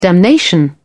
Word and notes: damnation: British English

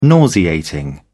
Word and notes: nauseating: British English

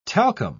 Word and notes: talcum: British English

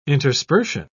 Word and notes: interspersion: British English

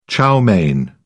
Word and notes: chow mein: British English

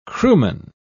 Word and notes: crewman: British English

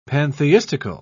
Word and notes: pantheistical: British English

